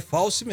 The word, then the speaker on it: Fauci: É Fauci mesmo. Fauci mesmo? – Falou certo. Falei certo.